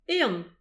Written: ehan

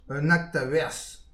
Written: un akt a werzh

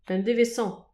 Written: un devezh hanv

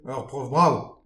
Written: ur prof brav

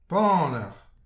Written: paner

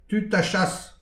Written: tud ha chas

